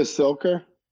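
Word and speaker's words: Cilker: I listened to Margo Clicker, which was your number one, right? – Oh, Margo Silker.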